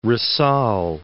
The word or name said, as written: Rizal